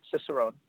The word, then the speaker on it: Cicerone: And that's kind of a good segue to what Cicerone, is that, am I pronouncing that right? Cicerone? – Yeah, that's right, Cicerone.